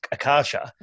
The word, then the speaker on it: Akasha: how to pronounce it, the answer was you can pronounce it like you like, but it's Akasha.